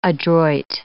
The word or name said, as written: adroit